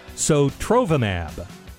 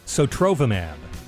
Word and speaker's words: Sotrovimab: All right, I think I finally figured out how to say the name of this drug that Governor DeSantis is bringing to Florida. Sotrovimab. Sotrovimab.